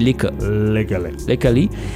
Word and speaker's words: Lykke Li: Donc, ça s'écrit L-Y-K-K-E-L-I, mais les E à la fin, il faut les prononcer. Ouais. Donc, c'est Lickley.